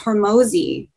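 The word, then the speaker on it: Hormozi: And Alex Hormozy, am I saying his name right? – Yeah, I think so.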